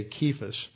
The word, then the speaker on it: Kephas: It's pronounced with a hard K because there's no such thing as a sibilant C in Greek. There's no sibilant sound there. It's like a K, Kephas.